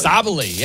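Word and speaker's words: Sabally: Let's, Sabali. Am I saying it right? Sabali. – Sabali, yeah.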